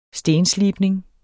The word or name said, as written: stenslibning